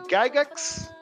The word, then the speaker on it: Gygax: que foi criado pelos, me corrija as pronúncias, uhum. – Gary Gygax, correto, e Dave Arneson.